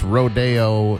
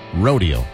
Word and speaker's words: Rodeo: It's not pronounced Rodeo. It's Rodeo.